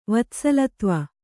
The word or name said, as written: ವತ್ಸಲತ್ವ